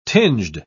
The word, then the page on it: tinged: British English